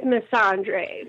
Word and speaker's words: Missandei: I think we went with Missandei.